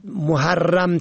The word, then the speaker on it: محرم: به گونه مثال محرم به ضم میم فتح ه و تشدید ر که باید محرم تلفظ شود،